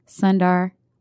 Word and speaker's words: Sundar: Sundar.